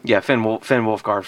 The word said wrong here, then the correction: Wolfhard: from, or Wolf, how do you pronounce it? Wolfhard. Finn Wolfhard.